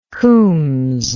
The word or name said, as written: khoums